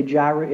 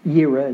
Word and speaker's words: Yireh: A lot of people say Jireh. It's Yireh is how it's supposed to be pronounced.